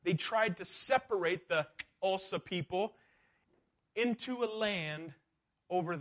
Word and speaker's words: Xhosa: It's home to the Xhosa people. That's how you say it. You can say it with me. Xhosa.